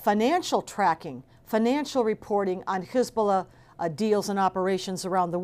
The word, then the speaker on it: Hezbollah: Remember, the name Hezbollah itself, and again, why I pronounce it Hezbollah, is because Hizb means party, Allah, God, party of God, right?